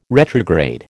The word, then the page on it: retrograde: American English